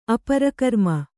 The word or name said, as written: ಅಪರಕರ್ಮ